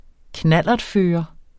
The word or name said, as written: knallertfører